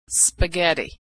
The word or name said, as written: spaghetti